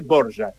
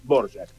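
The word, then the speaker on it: Dvořák: D-B-O-R-A-K. Muchas veces decían Anthony Dvorak, que no está mal, pero se dice Dvorak. La pronunciación correcta es Dvorak.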